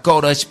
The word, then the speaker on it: Courage: pero se pronuncia Courage porque es en inglés couraje latino, coraje latino.